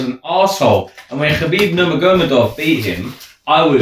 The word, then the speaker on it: Nurmagomedov: No, say his name properly. – That is his name. – No, it's not his name. – Is it Numegum? – Nurmagomedov. – It's not. Numega? – I'll look it up.